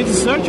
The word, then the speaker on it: Desert: Porque Desert é uma coisa e Desert é outra, cara. Muito obrigado. – É mesmo? Um dos dois é sobremesa, não é? – Exato.